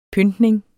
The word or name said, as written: pyntning